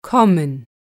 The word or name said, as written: kommen